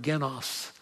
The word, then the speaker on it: genos: Greek doesn't have a soft G, but we bring it over in words like genealogy. But it's the word genos.